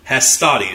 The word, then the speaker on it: Hastati: In the first row, they had the group called the Hastati. Hastati. Sorry, I can never say that. Hastati is the correct way to say it.